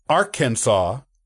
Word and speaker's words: Arkansas: Interestingly, the pronunciation of the state was only settled as Arkansas with an act of the state legislature in the 1880s. Before that, it was more commonly pronounced as Arkansas. And some local people still use that pronunciation for the nearby Arkansas River.